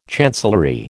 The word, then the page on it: chancellery: American English